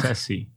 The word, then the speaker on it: Scorsese: Scorsese.